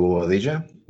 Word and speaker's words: Bobadilla: And this is Leo Bobadilla, which, as you all know, rhymes with quesadilla.